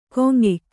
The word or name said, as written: ಗುಂಯ್ಕ